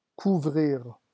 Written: couvrira

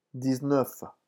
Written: dix-neuf

